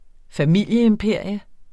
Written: familieimperium

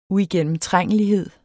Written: uigennemtrængelighed

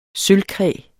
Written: sølvkræ

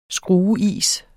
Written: skrueis

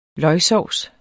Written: løgsovs

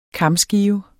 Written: kamskive

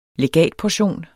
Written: legatportion

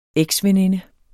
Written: eksveninde

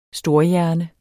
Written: storhjerne

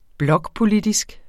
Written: blokpolitisk

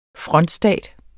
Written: frontstat

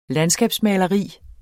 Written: landskabsmaleri

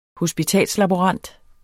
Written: hospitalslaborant

